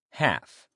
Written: half

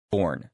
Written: born

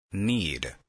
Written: need

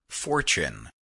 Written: fortune

